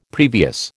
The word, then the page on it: previous: American English